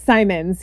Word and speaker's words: Simons: So Jill Simons. Jill, am I saying that right? Is it Simons or is it Simon? – It's Simons, yes. – Simons with an S on the end.